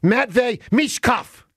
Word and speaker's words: Matvei Michkov: Matvei Michkov.